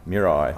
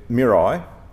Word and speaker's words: Mirai: Can you pronounce the, I did write it down phonetically before, the Mirai? – Mirai. – I got it. – Yeah. So Mirai.